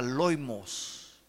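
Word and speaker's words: loimos (λοιμός): Esta palabra pestes viene de una palabra griega que se pronuncia loimos, loimos, que significa, escúcheme bien, plaga.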